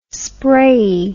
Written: sprayey

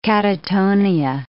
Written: catatonia

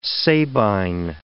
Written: Sabine

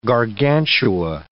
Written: Gargantua

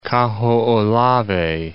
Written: Kahoolawe

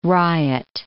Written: riot

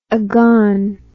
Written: aghan

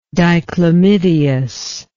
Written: dichlamydeous

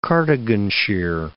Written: Cardiganshire

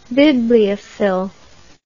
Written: bibliophil

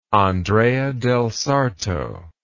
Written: Andrea del Sarto

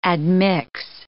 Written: admix

